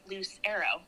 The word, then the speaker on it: Lucero: The story from that is, you know, when you like get your diploma or whatever from high school and they ask you to write out how to say your name. I remember talking to my dad and he said, just say Lucero.